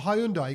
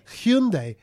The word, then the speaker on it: Hyundai: He goes, one guy to another guy, and he goes, I've got a Hyundai. He goes, Hyundai, you mean Hyundai? – Hyundai. – Hyundai. And he goes, it's not pronounced Hyundai, pronounced Hyundai.